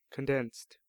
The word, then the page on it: condensed: American English